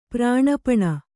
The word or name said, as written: ಪ್ರಾಣಪಣ